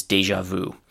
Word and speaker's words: déjà vu: That's actually the French pronunciation, and the pronunciation in English is déjà vu because here we have actually an, an established English pronunciation. I'm going to use the English one instead of the original French.